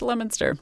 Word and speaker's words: Leominster: We'll help on the date and the Massachusetts town, which is actually pronounced Leominster. That's the fault of your people, Nikki. – These ridiculous British names.